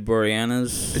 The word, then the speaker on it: Boreanaz: Tara Reid, Danny Trejo, David Boreanaz. – Is that how you pronounce his name? That's why I hesitated.